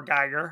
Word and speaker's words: Giger: Giger, or Geiger, however you want to pronounce it, cited this as one of the greatest films he had ever seen.